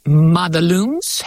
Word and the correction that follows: Madelung's: It's pronounced Madelung's deformity, and I can set the scene for you a bit.